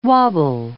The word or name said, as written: wobble